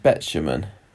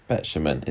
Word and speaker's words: Betjeman: Betjeman. Betjeman. It's got the phonetic alphabet on Wikipedia.